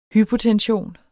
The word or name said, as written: hypotension